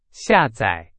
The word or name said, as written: 下载